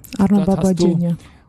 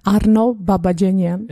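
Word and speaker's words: Arno Babajanjan (Առնո Բաբաջանյան): Genau, Arno Babajanjan. – Okay, richtig ausgesprochen. Sagst du es nochmal bitte? – Arno Babajanjan.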